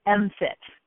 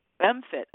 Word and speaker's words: MMFT: We actually pronounce the acronym MFIT. – MFIT.